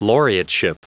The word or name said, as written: laureateship